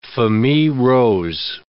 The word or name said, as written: famille rose